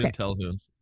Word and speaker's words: Jin Talhoun: So I just want to say, like, you did a good job by introducing your main character, which is Jin Talhoun. Am I saying that right? – Yep, okay. Jin Talhoun.